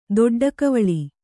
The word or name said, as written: ದೊಡ್ಡಕವಳಿ